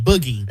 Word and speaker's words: bougie: And I would spell it B-O-U-G-I-E. Is that wrong? – You are correct. – That's how I spell it. Yeah. – When you're bougie, you know how to spell bougie. – Like boogie.